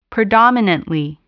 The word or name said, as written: predominantly